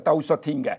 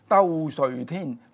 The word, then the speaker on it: 兜率天: 嗱，從俗你可以叫讀呢個兜率天嘅，但係佛教咧，我哋咧多數咧將佢咧讀成咧就係兜率天。點解咧？因為咧梵文咧係兜率陀，咁樣咧佢哋咧就譯咗呢兩個字，咁樣咧就係咧咁好多佛教徒咧就知道咧，我哋唔會讀佢做兜率天，要讀佢做兜率天，但係冇所謂嘅，從俗咧。